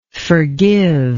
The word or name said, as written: forgiver